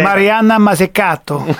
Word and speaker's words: Mariana Mazzucato: Many of your readers probably already know about her because it's pronounced Mariana Mazzucato. – Well, there you go.